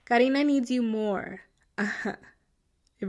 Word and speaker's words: Karena: So in the early mornings of September 7th, so the next morning, her roommate Karena, her name is spelled Karena, by the way, but it's Karina.